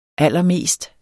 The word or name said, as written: allermest